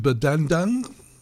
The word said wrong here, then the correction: Berdendang: Berdendang. – Berdendang, yes.